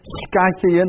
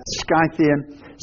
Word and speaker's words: Scythian: No Greek or Jew, circumcised or uncircumcised, barbarian, Scythian, that's how you pronounce it. If you understand, S-K-Y would be an easier way to understand that. Scythian, slave or free.